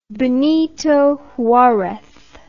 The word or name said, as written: Benito Juarez